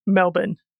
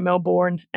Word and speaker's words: Melbourne: So if anyone is going to visit Melbourne in Australia at any point, it is Melbourne. But yeah, Americans say Melbourne and it annoys us all.